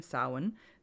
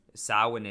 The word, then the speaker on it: Samhain: Yeah, because like Samhain is salmon. That's right.